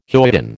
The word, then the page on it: hoyden: American English